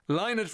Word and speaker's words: Lynott: So I'll be saying Lynott from here on in, and people will look at me and they'll laugh, but I will say that's the correct pronunciation.